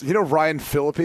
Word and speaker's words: Ryan Phillippe: I remember, so I went to this party, and I was, Ryan Phillippe, is that how you pronounce his name? – Yeah.